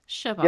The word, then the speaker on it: Chbosky: It should be Chbosky because it's a CHB. – Right. – So, yeah, we're missing vowels. Chbosky. It's a whole thing. It's a whole family thing.